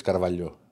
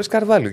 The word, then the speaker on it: Καρβάλιο: Δεν το λε Καρβαλιό. Όχι, το λε Καρβάλιο γιατί έτσι προφέρεται.